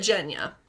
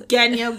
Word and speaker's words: Genya: I said it Jenya. – Apparently that's the way Leigh Bardugo says it pronounced. I thought it was Genya, but it's fine.